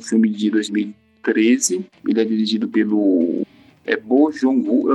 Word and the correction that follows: Bong Joon-ho: eu não sei como se pronuncia, tá? Bong Joon-ho. Bong Joon-ho, isso.